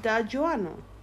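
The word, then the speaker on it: D'Aguanno: Da Juano.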